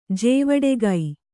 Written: ಜೇವಡೆಗೈ

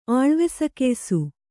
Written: ಆಳ್ವೆಸಕೆಯ್ಸು

